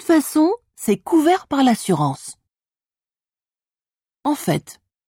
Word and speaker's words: en fait: En fait, j'ai arrêté de fumer il y a 2 mois. Bon à savoir. As with au fait, you also pronounce the T in en fait.